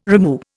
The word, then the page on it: rimu: Indian